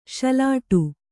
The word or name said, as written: ಸೊಲ್ಲಿಡು